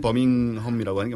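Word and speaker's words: Birmingham: 사실 영국식으로 발음하려면 버밍엄이라고 하는 게 맞죠.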